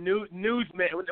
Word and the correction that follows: Newsom: however the hell you pronounce his last name. – Newsom, that guy's a joke.